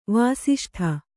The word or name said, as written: ವಾಸಿಷ್ಠ